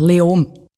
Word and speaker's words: Leoben: Spricht man es Leoben aus? Wie spricht man das überhaupt aus? – Eigentlich fast kein Konsonant. Leom.